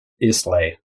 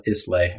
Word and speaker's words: Islay: So for example, Isla, if you look at it on paper, it looks like Islay. – Mm-hmm. – So don't call it Islay, everybody. It's pronounced Isla.